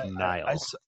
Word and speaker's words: Nihil: It says the main villains in this era are going to be called the Nihil, N-H-I-L, but pronounced Nihil. – I saw it as N-I-H-I-L. – N-I-H-I-L, okay.